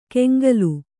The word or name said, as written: ಕೆಂಗಲು